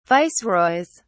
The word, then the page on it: viceroys: British English